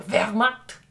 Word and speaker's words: Wehrmacht: The Wehrmacht? – Sure.